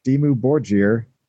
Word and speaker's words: Dimmu Borgir: Nagash was a bass player for Dimmu Borgir. Is that how you say it, Josh? I always mess it up. – Yeah, we're I think that was actually right.